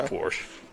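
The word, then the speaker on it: Portia: It's pronounced Porsche.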